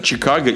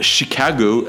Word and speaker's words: Chicago: И то же самое в Америке в, на севере, например, не говорят Чикаго и Мичиган. Они говорят Chicago and Michigan.